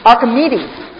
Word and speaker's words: Archimedes: A-R-C-H-I-M-E-D-E-S. – Archimedes.